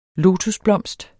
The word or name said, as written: lotusblomst